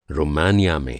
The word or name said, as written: रोमानिया